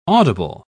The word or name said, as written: audible